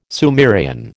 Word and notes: Sumerian: American English

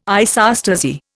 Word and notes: isostasy: Indian

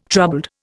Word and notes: troubled: Indian

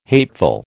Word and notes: hateful: American English

